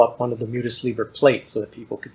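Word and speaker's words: Mutus Liber: In the appendix, you have the plates, these images from the, is it Mutis Liber? – I think that's the way it's pronounced, yeah.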